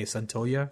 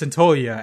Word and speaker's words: Santaolalla: It's Gustavo Santaolalla, which I had to look up how do you say that word, because it's spelled Santaolalla, and you just say Santaolalla.